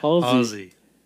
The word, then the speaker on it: Halsey: Halsey.